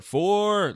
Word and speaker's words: Foer: I just say Jonathan Safran Foer. Foer, like you're playing golf. – Foer, that works.